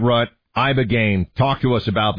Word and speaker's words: Ibogaine: Is that pronounced relatively properly, would you say? – Yep. – I don't want to get it wrong. Ibogaine, because it's so important, and experienced an 80 to 90% reduction in symptoms of depression and anxiety within 1 month.